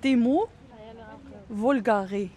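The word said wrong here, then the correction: Thymus vulgaris: Alors, on peut prononcer le S, donc c'est Timus vulgaris.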